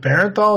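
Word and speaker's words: Bernthal: But there is something away about the way that is it Barenthal? Is that how you pronounce his last name? – Yeah.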